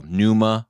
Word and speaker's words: pneuma: Yes, pneuma is spelled P-N-E-U-M-A. And again, that's the Greek word pneuma is for spirit or breath. – And the first P is silent.